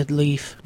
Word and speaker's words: Lief: I would say Leif. – I was saying Leif, too. – Okay. It's spelled L-I-E-F, but I always pronounced it Leif.